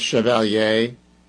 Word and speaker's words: Chevalier: And I pronounce it the French way, Chevalier. I don't know if it's Chevalier, like the way, you know, but think about Chevrolet. That's the way I'm going with it.